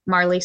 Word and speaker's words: Marli: Yeah, so my Instagram handle is just my name, Marli Scarborough.